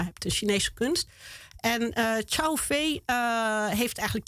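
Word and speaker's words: Cao Fei: Daar heb je bijvoorbeeld een kunstenaar gezien, Chao Fei. Zeg ik dat goed? – Chao Fei, ja, Chao Fei.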